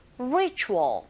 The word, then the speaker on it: ritual: Ritual 발음하실 때 R 사운드 처음 소리 내실 때 잘 안 되시면 힌트는요. 우 하면서 re 하시면 돼요. Ritual. 그런데 이때 혀는 어디에도 닿지 않게 발음하셔야 됩니다.